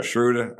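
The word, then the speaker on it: Schröder: How you say his name? Schroeder? – Schroeder. – I said it right.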